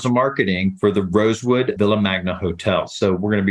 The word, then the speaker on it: Villa Magna: And I want to be sure I'm saying this correctly, as I would say it if I was in Spain. So you help me out. I would say Villa Magna. – It is ideal. Villa Magna.